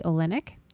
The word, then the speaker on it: Olynyk: Number 13, Kelly Olynyk.